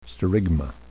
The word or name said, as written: sterigma